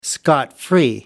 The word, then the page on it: scot free: American English